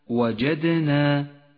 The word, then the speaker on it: وجدنا: وأخيرا صفة القلقلة لحرف الدال في وسط كلمة وجدنا، وجدنا لونت الدال بالأزرق الفاتح إشارة إلى صفة القلقلة.